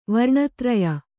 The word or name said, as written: ವರ್ಣತ್ರಯ